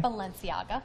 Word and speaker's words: Balenciaga: A new item from luxury retailer Balenciaga. Did I say that right? – Balenciaga. – Thank you.